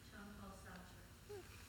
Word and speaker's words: Jean-Paul Sartre: Jean-Paul Sartre.